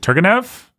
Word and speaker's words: Turgenev: It's Turgenev. – I've heard it pronounced so many different ways. Is it Turgenev? – I say Turgenev, but I don't know.